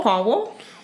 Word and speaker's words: Poirot: Poirot.